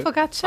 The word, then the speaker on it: focaccia: Focaccia.